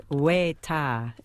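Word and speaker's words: wētā: Yes, so it's weta. It's a long E and a long A. So they have macrons on both the E and the A.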